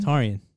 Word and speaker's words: Taurion: Tarian. – How do you spell it? – T-A-U-R-I-O-N.